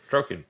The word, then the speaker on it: Droqen: It's like D-R-O-Q-E-N, so Droken? Yeah. – Droken.